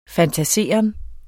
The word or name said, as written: fantaseren